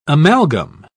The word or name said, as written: amalgam